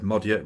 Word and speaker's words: Magyar: Just while I'm on it, you may well have seen the word Modia written down as Magyar, M-A-G-Y-A-R. Just believe me, it's pronounced Modia.